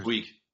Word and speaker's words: Puig: Did I say that right? Puig or Puig? – Yep. Puig.